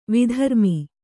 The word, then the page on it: ವಿಧರ್ಮಿ: vidharmi